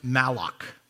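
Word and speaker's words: malach (מלאך): To begin, the Hebrew word for angel is pronounced malach.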